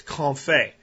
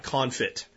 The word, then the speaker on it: confit: And if you want to look up information on it, it's spelled French, so it doesn't sound like it's spelled in English phonetically. It's C-O-N-F-I-T, like confit, is how you would phonetically pronounce it, but confit.